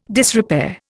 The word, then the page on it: disrepair: Indian